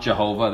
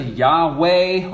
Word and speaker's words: Yahweh: It's four letters in the Hebrew alphabet, and some people pronounce it as Jehovah, but it's definitely not Jehovah. That's a butchering of the word. It's probably closer to Yahweh or Yahweh or something like that.